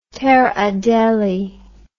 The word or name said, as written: Terre Adelie